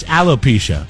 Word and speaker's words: alopecia: I thank God propecia wasn't like an erectile dysfunction drug or something like that. I was like, wait a second, did I say that right? No, it's alopecia.